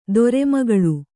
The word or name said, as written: ಧರ್ಮಗಾಳೆ